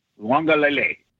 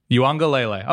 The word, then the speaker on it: Uiagalelei: DJ Uiagalelei. – Uiagalelei.